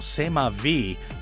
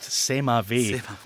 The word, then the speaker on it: C'est Ma Vie: Their last single, C'est Ma Vie, that's not mispronounced, C'est Ma Vie, was